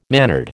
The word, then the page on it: mannered: American English